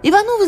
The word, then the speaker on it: Иванов: Ударение у Николая Константиновича падало на последний слог. Фамилия его читалась Иванов.